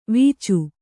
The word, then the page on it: ವೀಚು: vīcu